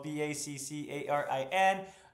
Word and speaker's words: Baccarin: Sean Mayer, who plays Simon Tam, River's brother, Morana Backlin, excuse me, I mispronounced that as well, Baccarin, and Nora.